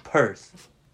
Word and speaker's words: Perth: Perth? – Am I pronouncing it right? Like Perth.